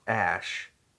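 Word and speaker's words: Æ: just X, the letter X, and then the AE thing is pronounced ash, and then A twelve is my is my contribution.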